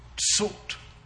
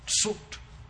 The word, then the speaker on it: Zucht: And the word for sound mind in German is spelled Z-U-C-H-T. And you're supposed to pronounce it tsucht, tsucht. Well, unfortunately, when I got to it, I was lazy, and I said, Zucht, which to them is spelled S-U-C-H-T and means addiction.